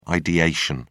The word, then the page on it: ideation: British English